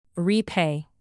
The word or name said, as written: repay